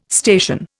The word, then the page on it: station: Indian